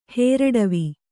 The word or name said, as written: ಹೇರಡವಿ